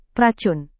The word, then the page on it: racun: Indonesian